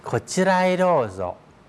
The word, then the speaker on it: こちらへどうぞ: こちらへどうぞ